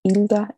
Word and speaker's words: Hilda: Hello, my name is Hilda Eunice Burgos. In Spanish, my first and middle names are pronounced Hilda Eunice.